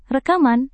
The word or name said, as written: rekaman